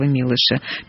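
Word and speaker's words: Милош: Все-таки так надо ставить с ударение Чеслова Милыша.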